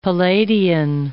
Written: Palladian

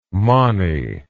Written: mony

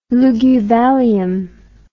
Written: Luguvallium

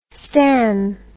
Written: stann

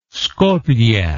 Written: skoplje

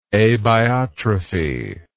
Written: abiotrophic

